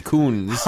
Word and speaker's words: Kuntz: K-U-N-T-Z, Koons. – Oh, it's spelled that way.